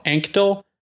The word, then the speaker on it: Anctil: A-N-C-T-I-L. Anctil? How would you pronounce that? A-N-C-T-I-L. – Anctil? – That's what I thought.